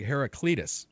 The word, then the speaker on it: Heraclitus: I when I first glanced at it, I thought it said Hercules, but it looks like Heraclitus. Is that pronounced right? – Yeah, I believe that's it.